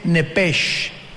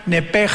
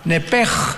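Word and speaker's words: nepej (נפש): Los que quieran apuntarlo, la palabra alma en el hebreo es nepesh, con SH al final, como suena nepesh. Pero la SH en hebreo se pronuncia como J, entonces se pronuncia nepej, como el francés, ¿verdad?, que es gutural, nepej.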